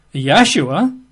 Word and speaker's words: Yahshua: That's Y-E-S-H-U-A, or sometimes Y-apostrophe, S-H-U-A, Yeshua. Or, of course, it could be Yahshua, Y-A-H-S-H-U-A, Yahshua. But then it can also be Yahushua, extra syllable there, Y-A-H-U-S-H-U-A, Yahushua.